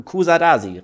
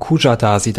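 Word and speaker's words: Kuşadası: Wir fahren in die Türkei nach Kusadasi, richtig? Manche sagen auch Kujadasi dazu.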